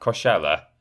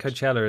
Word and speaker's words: Coachella: How do you pronounce that? Coachella? – I think Coachella is right.